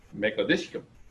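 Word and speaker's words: Mekaddishkem: So Mekadeshkim, I heard the real pronunciation. It goes Mekadeshkim. That's how you say it.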